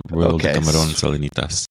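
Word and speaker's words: Royal Decameron Salinitas: The beautiful Royal D. Cameron Salinitas. I'm not sure if I'm pronouncing that. Yeah, there you go. That's right. Yeah, Royal de Camarón Salinitas.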